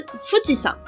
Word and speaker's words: 富士山: 名字的由来，富士山（Fujisan）。